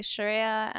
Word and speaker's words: Shireya: Usually people will be like Shireya, but it's pretty phonetic, like how it's spelt. So it is I, Shireya Anastasia, and I do art, and yeah, I don't know what else.